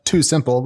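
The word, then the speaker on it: TuSimple: It surprised me because I've tracked Too Simple, which is spelled T-U Simple, which does self-driving trucks for a long time.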